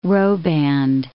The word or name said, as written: roband